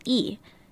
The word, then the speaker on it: Rhee: In Korean, Syngman Rhee is pronounced differently. What many say as Rhee is actually the last name Lee. In Korean, you'd actually say Lee Seung-man.